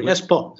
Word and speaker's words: Espoo: Espoo. Is that how it's pronounced? – Yeah, right.